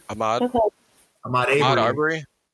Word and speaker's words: Ahmaud Arbery: Ahmaud Arbery.